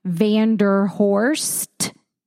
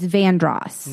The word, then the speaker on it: Vandross: So, like, it's spelled, like, if you were to see the street, you would think it was pronounced Vanderhorst. But if you were local to the area, it's Vandross.